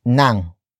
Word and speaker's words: ng: And then we have nang, which is spelled as ng.